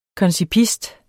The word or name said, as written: koncipist